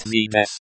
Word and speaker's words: Здесь: Pronounced Zdes, this is the most common translation of here, and means here, in the sense of a location. The stress is on the first syllable, Z.